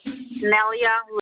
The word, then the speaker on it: Nellya: Nelia Lecht. – You said Nelia, spell that for me. – N-E-L-L-Y-A.